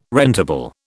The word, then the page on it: rentable: British English